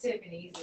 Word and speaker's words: Typhanie's: It's Typhanie's.